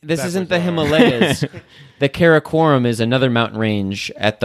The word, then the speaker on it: Karakoram: Is it the mountain range? Karakoram? – It's Karakoram. Yes. – Karakoram. Is that how you say it? – Exactly. Yep.